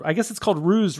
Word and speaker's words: Roes: And it's called Ruse Red, because I'm pronouncing it correctly now. Ruse, R-O-E-S, kind of like a play on Rose Red, but it's a delicious beer.